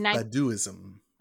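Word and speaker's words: Baduizm: She was signed to a record label in 1994 and released her first album, Badzium. I think I said that right. Badduism? Badduism? – I think it's Badduism. – Badduism? Oh, it is. That is exactly what it is.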